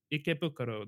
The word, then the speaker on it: Ikebukuro: So Ikebukuro, that's how it would be pronounced, yes.